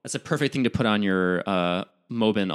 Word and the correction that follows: Mabon: altar. Sorry. wait, is that what? How do you say it? – Mabon. – It's the perfect thing to put on your Mabon altar.